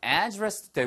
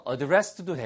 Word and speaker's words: address: 발음은 두 가지입니다. 우리가 주소라고 할 때는요. address도 되고요. address도 돼요. 그런데 저는 개인적으로는 address를 많이 선호를 하고요.